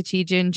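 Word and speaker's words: Tietjen: I'm on all the platforms as Jessica Chijin, JD.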